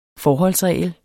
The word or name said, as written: FORHOLDSREGLER